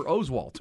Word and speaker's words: Oswalt: Roy, why are you letting them pronounce our name wrong? We're Oswalt.